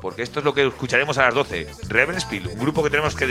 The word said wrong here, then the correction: Rebel Spell: Es la Rebel Spell. – Sí, siempre lo digo mal, disculpadme, podéis corregirme.